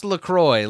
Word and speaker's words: LaCroix: It says, pronounce LaCroix like enjoy.